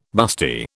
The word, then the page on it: busty: British English